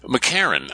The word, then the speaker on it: McEachern: But McArron, I just don't get it. You know, there are a lot of names like that, but it's spelled McEachern, E-A-C-H-E-R-N. Even at the end, we say McArron. It's like there's nothing between the R and the N.